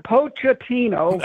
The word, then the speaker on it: Pochettino: And they were expected – Coach Pochettino, am I saying that right? Pochettino. – That's right.